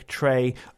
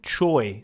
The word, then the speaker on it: Choi: Erica Trey, although it's spelt in the English version, Choi, C-H-O-I. I say that in case you Google her, but also because it comes up like about, she says, Erica Choi later on. So apparently Trey, or I hope I'm saying it the best I can, is how it should properly be pronounced from Korean. So yes, Erica coming up very soon indeed.